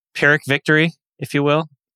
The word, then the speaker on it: Pyrrhic: Pyrrhic, I believe, is how you say it. Let me... – Yeah, P-Y-R-R.